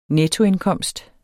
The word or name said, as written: nettoindkomst